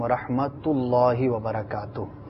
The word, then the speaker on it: ورحمۃ اللہ وبرکاتہ: اصلاح کی نیت سے یہ عرض کرتا چلوں کہ ورحمت اللہ وبرکاتہ درست تلفظ نہیں, بلکہ جیسے آپ نے کہا السلام علیکم ورحمۃ اللہ وبرکاتہ, تا پر جو ہے وہ پیش ہے, تو پیش کے ساتھ جو ہے اس کو ادا کیا کریں تاکہ جو ہے یہ آپ کا سلام کے جو الفاظ ہیں وہ مکمل طور پر اچھے انداز پر ادا ہو سکے.